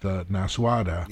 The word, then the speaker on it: Nasuada: With pictures of what was going on with Rohirrim and pictures of what was going on with Nasuada. – Yep. – If I'm saying that correctly. – You are. That's the way they pronounce it in the audio.